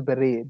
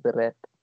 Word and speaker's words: beret: A beret, it's pronounced beret, but some people pronounce it beret.